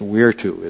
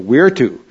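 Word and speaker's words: virtus: And so Julius Caesar, he had, in Latin, it's virtu is how they would say it. They pronounce the V with a W. So virtu, you know, virtu.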